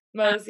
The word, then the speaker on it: Mohs: Is that how you pronounce it? Mohs? – Yeah, Mohs, yeah.